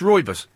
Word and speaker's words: Rooibos: I-B-O-S. – I-B-O-S. Oh, well, that's Rooibos.